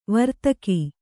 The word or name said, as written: ವಾರ್ತಾಕ